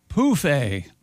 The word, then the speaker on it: pouffe: Anyway, Europeans of a certain age use the French word pronounced poufé for an ottoman. Phonetically spelled P-O-O-F hyphen A.